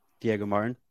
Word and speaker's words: Diego Martin: Am I saying this correctly? – Diego Martin.